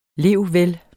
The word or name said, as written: levvel